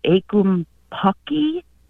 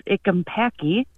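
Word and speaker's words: ackumpucky: And as I was trying to find this word and find something, I came across a word, I think you would pronounce it ickumpacky.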